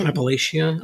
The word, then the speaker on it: Appalachia: Is that how you say it? – Appalachia. Okay. It was how I would say it, whether that's right or wrong.